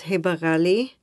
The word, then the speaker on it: Heba Ghali: Yeah, my name is Heba Ghali, and I've been here for 12 years.